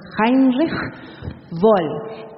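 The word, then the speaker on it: Heinrich Böll: Él es el director de la Fundación Heinrich, dice mi esposo que se pronuncia Heinrich Boll, quien tuvo la bondad de imprimir este libro tan inquieto.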